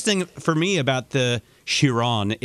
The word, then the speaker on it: Chiron: I'm obsessed with the car, which I incorrectly pronounce as Chiron. And I have been, by the way, I even do that with Steven Winkleman when I'm talking to the former CEO of Bugatti. And you can say it correctly. – Well, I would say Chiron. – Yes. – Or Chiron. Depending on if you're French or not.